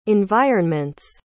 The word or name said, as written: environment's